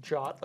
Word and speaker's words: JWT: And are you pronouncing that JOT, like J-O-T? – J-O-T is the pronunciation of J-W-T.